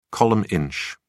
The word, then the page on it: column inch: British English